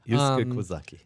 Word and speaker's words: Yusuke Kozaki: This is a character design by, I'm going to butcher this name, I'm sorry, Yusuke Kozaki. – Yeah, Yusuke Kozaki.